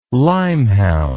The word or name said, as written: lymehound